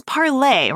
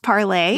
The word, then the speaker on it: Parler: It's Parler, right? Parler, yeah, is how you say it in French.